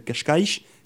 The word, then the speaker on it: Cascais: j'étais sur une petite cité balnéaire qui s'appelle Cascais, que mm. tu connais certainement. – Je l'ai bien prononcé Oui.